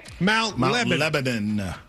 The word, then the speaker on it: Mount Lebanon: How do I say the town you're from? – Mount Lebanon. – Lebanon, okay. Like we say it in Kentucky, Mount Lebanon.